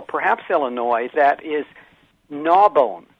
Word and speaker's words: Narbonne: And in fact, I think that's it's actually spelled like that, N-A-R-B-O-N-N-E, but that's not the way people there pronounce it.